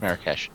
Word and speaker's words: Marrakech: The film's animal trainer went looking for a blue-eyed camel in the Marrakech market. I think I pronounced that right. Marrakesh? You're probably right. – I think it's Marrakesh.